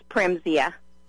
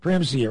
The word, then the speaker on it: Primezia: It's Primezia. – Primezia, okay.